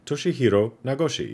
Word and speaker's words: Toshihiro Nagoshi: Uh, Toshihiro Nagoshi. I hope I totally didn't butcher that. Toshihiro Nagoshi. Yeah, there we go. That sounds a lot better.